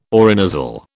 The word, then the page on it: orinasal: British English